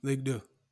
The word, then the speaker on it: Ligue 2: You're in your second season in Ligue 2. Did I say that right? Deux? – Ligue 2.